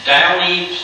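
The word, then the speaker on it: Duany: Downey, Platter, and Zylock.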